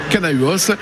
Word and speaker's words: Canahuos: Séverine, vous, votre entreprise, c'est Canahuos, on prononce comme ça ? – Exactement. – Canahuos.